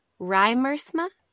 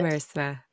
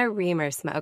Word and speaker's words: Riemersma: That's what it looks like, how it's spelled, but it's pronounced, yeah. It's pronounced Riemersma. It's Dutch. – Riemersma. – Yeah. – Jenna Riemersma.